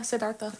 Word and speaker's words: Siddhartha: Yeah, Siddhartha.